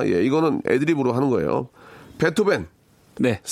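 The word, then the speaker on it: Beethoven: B E E T H O V E N입니다. 그래서 베 그대로 읽으면 베트호벤. 베트호벤. 예. 베트호벤. 다시 한번 해주기 독일 독일식 발음을 해주시기 바랍니다. 베트호벤. – 베트호벤. 예예 예, 알겠습니다. 독일식 발음으로 다시 한번요. 베토벤.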